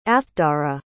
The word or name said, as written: athdara